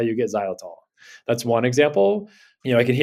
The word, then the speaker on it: xylitol: So one of the chemicals that's in your toothpaste, right, that's in your mouthwash, is a sweetener called xylitol. – I always wondered how to pronounce that. It's the one that's X, Y.